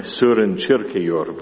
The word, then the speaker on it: Søren Kierkegaard: It's a theme that I take from the Danish philosopher Søren Kierkegaard, or I understand he should be pronounced something like Søren Kierkegaard, but I won't do that.